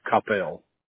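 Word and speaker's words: Coppell: Is that how we say Koppel, Texas? – You will not be the first. It's Koppel. – Koppel. Okay. All right. So you guys are based in Koppel.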